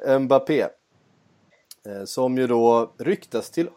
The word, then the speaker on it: Mbappé: Eh, ja, Mbappé, är det så? – Eller, du kör eh, uttalet Mbappé heter han. Mbappé.